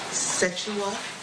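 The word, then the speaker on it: Sekyiwa: Setua Shakur. – May I have you spell it? – S-E-K-Y-I-W-A S-H-A-K-U-R.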